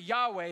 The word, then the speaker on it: Yahweh: Some people pronounce it Yahweh. Have you heard that? Yeah, so they just drop a couple of vowels where it seems to make sense. Another way to maybe pronounce it is Jehovah. Y's and J's kind of interact. W's and V's maybe the same.